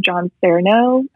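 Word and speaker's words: John Sarno: She studied under Dr. John Cerno. I believe that's how you say his name. – Yep. – And she taught me this practice called journal speak.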